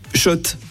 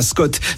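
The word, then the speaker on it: Schott: Oui, Shot. Et pas Scott, ça fait des années qu'on prononce mal la marque.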